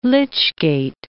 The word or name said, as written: lych-gate